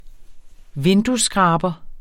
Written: vinduesskraber

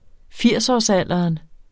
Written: firsårsalderen

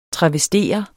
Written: TRAVESTEREDE

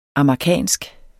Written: amagerkansk

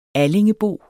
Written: allingebo

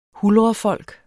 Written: huldrefolk

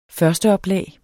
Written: førsteoplag

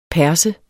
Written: perse

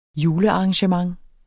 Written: julearrangement

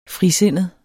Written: frisindet

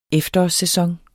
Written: efterårssæson

